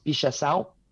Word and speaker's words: Pichação: It's written P-I-X-A-C-A-O, like Pixacao, but it's Portuguese and it's pronounced Pichação.